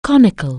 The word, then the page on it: conical: anglais